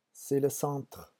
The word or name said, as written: c'est le centre